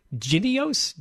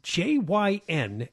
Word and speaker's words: Jynneos: Boy, it's pronounced Jynneos, Jynneos. It can be used to treat both the monkeypox and the smallpox.